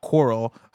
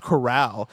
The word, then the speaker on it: corral: Portland Kev says Tim and Blessing pronounced the name Outlaw Coral like the word coral as in the ocean organisms, coral reefs. The word should be pronounced corral as in the country style containment.